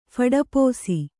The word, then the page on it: ಫಡಪೋಸಿ: phaḍapōsi